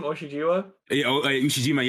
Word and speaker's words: Ushijima: Wasn't it sick when Hinata spiked the first ball down and how do you say his name? – Ushijima, yes. Received it? – Yeah.